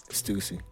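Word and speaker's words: Stüssy: I'm like, is it Stussy? Stussy? – I don't know. – How you say it? – Stussy. – Stussy. You're gonna edit stylish. Me. You motherfuckers gonna edit me and make sure I say it right.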